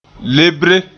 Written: lebre

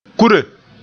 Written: kure

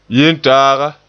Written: yentaaga

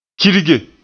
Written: kirge

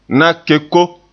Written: na-keko

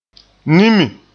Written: nimi

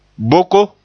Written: boko

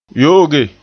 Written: yo'oge

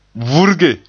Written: vurge

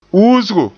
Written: uusgo

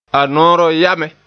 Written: ya

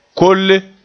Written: kolle

